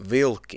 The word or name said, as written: Vilki